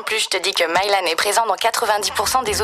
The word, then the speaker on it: Mylan: Mylan. Même avec une voix pareille, ça se prononce Mylan.